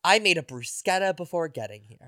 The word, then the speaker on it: bruschetta: And thank you for saying that properly. – Is that how you say it? – Yes. – Yeah. I work in an Italian restaurant. – I've gone to Italian restaurants where they still say bruschetta, and I'm like, it's not how you say it, doll.